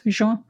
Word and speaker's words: Hozho: You know, so this notion and linking this concept and this story, the narrative around Hozho, is that how you say it? – Yeah. Yes. Hozho.